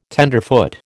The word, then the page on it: tenderfoot: American English